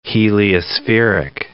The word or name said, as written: heliospheric